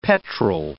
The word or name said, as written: petrel